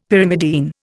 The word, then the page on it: Pyrimidine: Indian